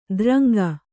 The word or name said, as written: ದ್ರಂಗ